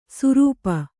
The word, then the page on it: ಸುರೂಪ: Kannada